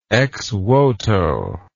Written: exvoto

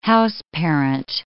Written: houseparent

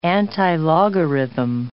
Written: antilogarithm

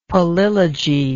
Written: palilogy

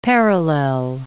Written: parallel

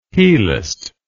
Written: heliced

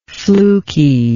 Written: flukiness

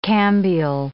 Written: cambial

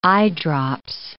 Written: eyedrops

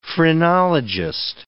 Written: phrenologist

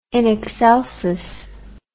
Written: in excelsis

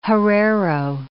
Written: Herero

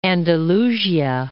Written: Andalusia

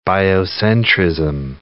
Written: biocentrism

